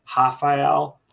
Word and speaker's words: Rafael: You don't pronounce the R, so it's actually Rafael.